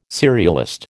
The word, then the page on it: serialist: American English